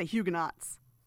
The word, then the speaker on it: Huguenot: Huguenots. – Huguenots. – If you are French, you call it Huguenot.